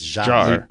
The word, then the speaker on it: Jarre: Well, let's just say By the way, it's not Jarret. It's Jar.